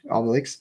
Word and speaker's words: Obelix: Obelix.